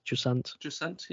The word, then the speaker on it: Jusant: And then we got to Jusant. Is that how you pronounce it? Jusant? – Jusant, yeah.